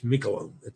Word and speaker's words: Miquelon: Following standard French pronunciation, Saint Michaelin, as in bone. Miquelon. But since it's Canadian French, it would have its own regional pronunciation. Pretty sure it's Miquelon. I know the first syllable has all the emphasis.